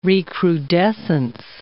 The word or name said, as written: recrudescence